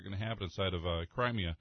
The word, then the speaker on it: Crimea: Crimea? I don't even know if I'm saying that right. – Crimea.